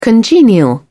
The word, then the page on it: congenial: British English